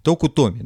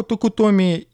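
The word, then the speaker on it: Токутоми (徳富): Токутоми, да. А, Соха, э, Сохо Токутоми и младший Рока Токутоми. – Как ты здорово это произносишь.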